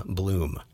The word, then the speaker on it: Bluhm: That is spelled B-L-U-H-M, Bloom.